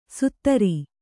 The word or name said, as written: ಸುತ್ತರಿ